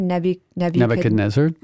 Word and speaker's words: Nebuchadnezzar: Nebuchadnezzar.